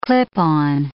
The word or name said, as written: clip-on